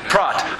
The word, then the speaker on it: prot: Prot. I like the prot, it rhymes with trot, like Trotsky, okay?